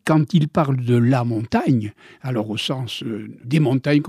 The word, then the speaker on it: Montaigne: Et je vais vous donner un exemple de gasconnerie ou de gasconnade, c'est que dans mon pays, on ne prononce pas le « i ». Et par conséquent, nous devrions l'appeler montagne.